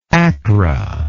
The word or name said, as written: Akkra